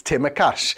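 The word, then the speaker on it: Tsimikas: He said it's Timokas. He said, you literally take the S out of it. I don't think that Timokas is going to be playing.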